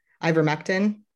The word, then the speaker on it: ivermectin: You know, the latest one was, I'm going to mispronounce it, so Justin might want to help me out here, but ivermectin. – I think you nailed it.